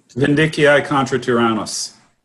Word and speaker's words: Vindiciae contra tyrannos: Vindiciae contra tyrannos.